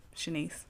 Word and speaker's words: Shanice: And it's a play on my name, Shanice, which is spelled Shanice, but She Nice.